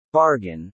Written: bargain

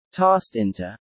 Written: tossed into

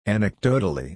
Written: Anecdotally

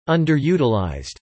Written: underutilized

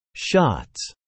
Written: shots

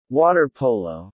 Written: water polo

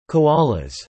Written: Koalas

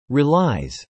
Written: relies

